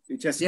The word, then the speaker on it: Lucescu: How do you say the name again? It's Lucescu, is it? Lucescu. – Yeah, that's fine.